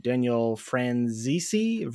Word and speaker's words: Daniel Franzese: Mean Girls is directed by Mark Waters, stars Lindsay Lohan, Rachel McAdams, Lacey Chabert, Lizzie Kaplan, Daniel Franzese.